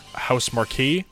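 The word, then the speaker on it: Housemarque: Next game, Returnal, a new franchise from Sony, which was shown during the event developed by formerly arcade-focused House Marquee. Did I say that right? – Yeah, Marquee, yeah.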